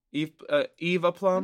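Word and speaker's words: Eve Plumb: How do you say that? – A plum? – A plum, yeah. Eve a plum? – I think you said it right.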